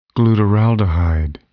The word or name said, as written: glutaraldehyde